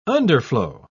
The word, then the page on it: underflow: British English